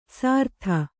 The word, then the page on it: ಸಾರ್ಥ: sārtha